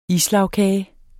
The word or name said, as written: islagkage